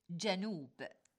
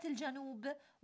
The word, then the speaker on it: جنوب: إخوتنا أخواتنا المستمعين والمستمعات، كثيراً ما يقع الخطأ في تلفظ كلمة جنوب التي ينبغي أن تلفظ بفتح الجيم لا بضمها، ونفس الخطأ الذي يقع في كلمة الجنوب وهو ضم الجيم يقع في كلمة القبول بفتح القاف وهو الصحيح.